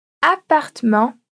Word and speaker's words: appartement: Mas também pode aparecer quando duas consoantes precedem a vogal E. Vamos repetir comigo os exemplos, então? S'asseoir, appartement, tortue et gouvernement.